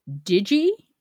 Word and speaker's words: Digi: This week's internet kitty is Digi. – Digi. – Digi. Kurt, one of Digi's humans, tells us that Digi is pronounced with a soft G.